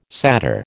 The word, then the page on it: satyr: American English